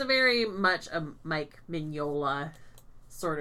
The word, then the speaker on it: Mignola: Mignola.